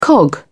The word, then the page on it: cog: British English